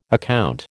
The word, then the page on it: account: American English